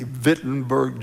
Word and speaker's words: Wittenberg: It's spelled W, but it's pronounced with a V, Wittenberg, Germany, and nailed his 95 theses to the door.